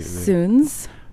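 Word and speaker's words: Suuns: We were debating how to say their name. It's S-U-U-N-S, and so... it could have been. – Yeah, I was pronouncing it Sons.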